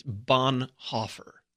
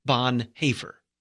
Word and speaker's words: Bonhoeffer: But if you look at the way it's spelled, the way Bonhoeffer spelled it, he spelled it Bonhoeffer, which is O with the two little dots over it, and that's pronounced with the A sound, Bonhoeffer.